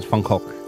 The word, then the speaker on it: Van Gogh: Can I say Van Gogh, by the way? Not Van Gogh. Is that okay? The actual uh, pronunciation is Van Gogh.